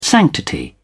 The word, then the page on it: sanctity: British English